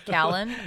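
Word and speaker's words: Kallen: Oh, Colin. Cal- Callan. I'm sorry. Callan.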